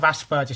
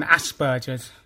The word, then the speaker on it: Asperger's: Yeah, I've got mild traits of Asperger syndrome as well as having dyspraxia. – Asperger's or Asperger? Solve this debate. How do you pronounce it? – I say the first one, Asperger's.